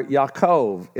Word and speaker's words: Yaakov: or Yaakov is the right way to say it.